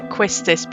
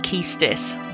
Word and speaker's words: Quistis: I've always pronounced it Quistis, but I have heard Keistis.